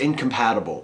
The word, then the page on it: incompatible: American English